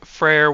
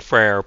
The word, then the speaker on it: Freyr: So, I've made this point before, but I'm saying Freyr because that's how it's spelled. You may also hear it as Frey. Both are correct. They're just different ways of saying it.